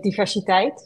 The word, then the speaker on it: diversiteit: Yeah, so in English it's diversity, and in Dutch you say diversiteit. So it doesn't really, it isn't really different, and inclusion and inclusie.